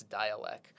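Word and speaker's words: Dälek: It's spelled D-A-L-E-K, so almost like Dalek, but they've got like an umlaut over the A, so it's Dialect.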